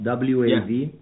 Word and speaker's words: WAV: how do you say WAV? WAV. – Yeah, WAV file. You got it right.